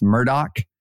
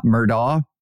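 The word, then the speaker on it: Murdaugh: And again, thanks to listeners who sent in and told us it is pronounced Murdoch, not Murdaw, but the Murdoch murders in South Carolina.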